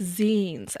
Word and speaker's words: zines: So it's pronounced zines, and it's because that's like a contraction from the word magazine.